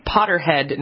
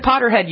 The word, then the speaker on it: Potterhead: Yes, we should say Potterhead, not Pothead. – Did I say Potterhead? – No, you said Potterhead, but okay. You said Potterhead.